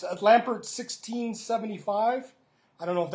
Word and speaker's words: Lampert: Am I saying it right? Yeah. L-A-M-P-E-R-T. Lampert.